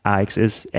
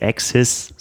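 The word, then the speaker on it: AXS: Das ist jetzt das, was SRAM mit der neuen Red E-Tab AX ist. AXIS, so spricht man es aus. AXIS.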